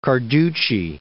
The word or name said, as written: Carducci